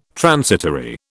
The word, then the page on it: transitory: British English